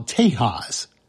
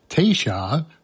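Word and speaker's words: Taysha: ZZ Top, right before that, an album I always call Tejas, probably more properly pronounced as Teisha from the Caddo language.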